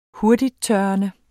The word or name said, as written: hurtigttørrende